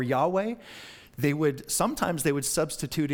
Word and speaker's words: Yahweh: They would never say Jehovah, or the better pronunciation is Yahweh, but they would never say that, and they would never even write it.